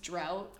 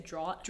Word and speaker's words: drought: The thing that I do that with is I know that drought is pronounced drought. – What do you say, draught? – Draught.